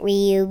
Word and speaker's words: Ryu: I pronounce it Ryu because I feel like it's just Ryu, not Ryu.